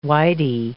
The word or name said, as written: whitey